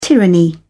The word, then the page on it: tyranny: British English